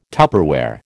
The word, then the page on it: Tupperware: American English